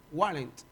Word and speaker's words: Wallenda: Pero si ustedes buscan en internet el nombre de Nick Walent, W-A-L-L-E-N-D-A, Wallent, si lo pronuncio mal, pues Vaya usted a pelear con el diccionario.